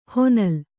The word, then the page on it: ಹೊನಲ್: honal